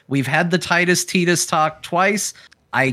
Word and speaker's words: Tidus: It's pronounced Tidus. Tidus. – Um, Tidus.